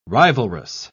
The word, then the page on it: rivalrous: British English